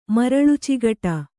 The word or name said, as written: ಮರಳುಚಿಗಟ